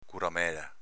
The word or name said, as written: curamela